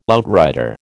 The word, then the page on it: outrider: American English